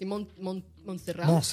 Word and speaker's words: Montserrat: Mentre si trovavano a Monster, oddio, come si pronuncia, ragazzi? Montserrat. Grazie.